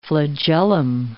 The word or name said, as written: flagellum